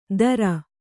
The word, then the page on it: ದರ: Kannada